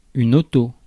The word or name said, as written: automobile